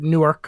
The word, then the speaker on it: Newark: Am I pronouncing that right? – Newark. Yeah, Newark could be fine, but Newark, I think, if you want to sound like you're from there.